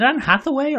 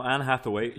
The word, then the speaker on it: Anne Hathaway: Is it Anne Hathaway or Anne Hathaway? – No, Anne Hathaway. You were right the first time.